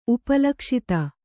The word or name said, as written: ಉಪಲಕ್ಷಿತ